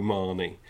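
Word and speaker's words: Marney: And then they said, well, how does that make you feel, Joe Marnie, which rhymes with biryani, which you're not happy about.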